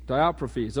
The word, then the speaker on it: Diotrephes: We'll get to the 6 marks of a how do you pronounce his name? Somebody tell me. Diotrephes? Diotrephes, okay. So make sure.